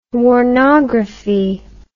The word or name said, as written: warnography